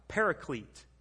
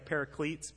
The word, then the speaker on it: paraclete: And that name, Advocate, in Greek is a word, paraclete. Can you say it like paracletes, but paraclete? Paraclete.